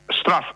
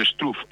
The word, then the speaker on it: Struff: Straff è numero 74, lo pronuncio così, qualcuno potrebbe dire Struff, io dico Straff.